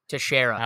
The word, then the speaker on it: Teixeira: Is Teixeira how you pronounce it? – Teixeira.